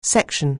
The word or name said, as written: section